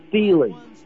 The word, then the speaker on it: Thiele: Yeah, his name is pronounced Chris Thiele. – Thiele, thank you. – No, it's hard to say. – Thiele. – T-H-I-E-L-E. Thiele. – Okay, Thiele. – As if rhymes with Thiele.